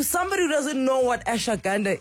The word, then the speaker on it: ashwagandha: And finally, we're going to have this conversation about ashwagandha.